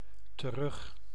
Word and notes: back: /bæk/

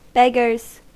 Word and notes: beggars: American English; /ˈbɛɡ.ɚz/

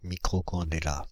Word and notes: microcandela: [mi.kʁo.kɑ̃.de.la]

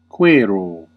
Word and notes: chercher: France; [ʃɛʁ.ʃe]

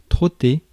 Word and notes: trotter: /tʁɔ.te/; France

